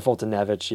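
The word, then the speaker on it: Foltynewicz: Fultonewicz. He's okay. Or Fultonevich, yeah. I always say his name wrong. It's a W, but it's pronounced V.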